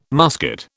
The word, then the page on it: musket: British English